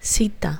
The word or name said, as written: cita